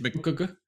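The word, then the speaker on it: McG: Is it McGee? – Yeah. – I've never said it. How would you pronounce it? I've just never said it out loud. I've never tried. It's one of those ones I don't want to mispronounce. Muck? – Yeah, it's McGee. Yeah, McGee, because his name is like McGinty, and he shortens it to McGee.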